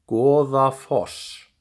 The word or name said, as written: Goðafoss